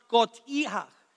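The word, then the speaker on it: skotiach (σκοτία): The word dark is pronounced skotiach. Skotiach.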